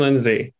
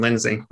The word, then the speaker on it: Lindsay: Ben Lindsay. – Ben Lindsay. – Yeah, just the women's name, Lindsay.